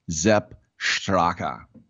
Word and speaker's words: Sepp Straka: But when you have a S with a vowel after it, you pronounce it as a Z. When you have it with a consonant after it, you pronounce it like an SH, i.e., Zepp Straka.